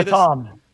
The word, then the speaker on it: LATAM: How do you say LATAM.